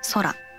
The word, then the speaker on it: 宇宙: 宇宙と書いて空と読むんですけど、